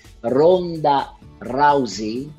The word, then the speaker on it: Ronda Rousey: Ora, io ho trovato anche dei nomi, francamente, bembe, che non conoscevo, tipo, ora me lo vado a leggere, Ronda Rousey, mm-hmm.